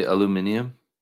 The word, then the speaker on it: aluminium: Does he say aluminium? – Aluminium, I believe is how it's pronounced.